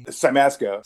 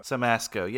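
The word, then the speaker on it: Siemaszko: Help me pronounce this guy's last name. Casey... – Simasco. – Simasco, yeah.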